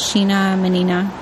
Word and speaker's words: Sheena Menina: And my Instagram name is Sheena Menina. Yes, it rhymes.